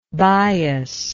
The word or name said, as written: biasness